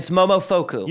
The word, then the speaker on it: Momofuku: Yeah, it's Momofuku.